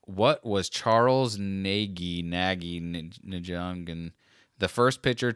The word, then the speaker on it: Nagy: N A G Y? Is that how you would still pronounce that? – I think so.